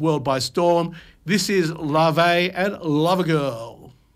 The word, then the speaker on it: Laufey: Folks, this song, his next song, is from Lave. I've been mispronouncing this lady's names. I said Laufey, L-A-U-F-E-Y. Lave is the Icelandic word.